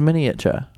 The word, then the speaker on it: miniature: That's how you say it. I always have to remind myself it's not. It's four syllables, not three. It's a miniature.